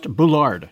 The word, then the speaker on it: Bullard: But because he spent most of his lifetime in France and because his father came from Martinique as a slave, it's likely pronounced Boulard. Hmm.